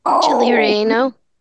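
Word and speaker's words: chile relleno: Chili relleno.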